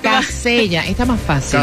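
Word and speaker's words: Kaseya: Se pronuncia Casella.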